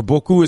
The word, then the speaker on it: beaucoup: There are two ways to say that, but beaucoup is how you say that word en français.